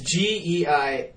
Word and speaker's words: Geisinger: I had a buddy from Germany that I met at Woodward, Max Geisinger. – I like how you said his name. – Yeah, I think that's how you pronounce it. – Try again. – It's Geisinger.